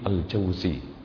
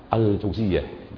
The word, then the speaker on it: Al-Jauziyah: Dan kata Imam Ibnul Qayyim, kalau dibaca Ibnul Qayyim Al-Jauzi, kalau dibaca Ibnul Qayyim tanpa al, Al-Jauziyah, itu ya, jangan sampai keliru cara membacanya.